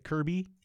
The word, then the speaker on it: Kirkby: K-I-R-K-B-Y. K-I-R-K-B-Y. Captain Kirk, B-Y. – Right. B-Y. Does the K drop? Is it Kirby? – Yeah.